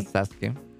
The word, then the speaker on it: Sasuke: ¿Se pronuncia Sasuke? – Sasuke.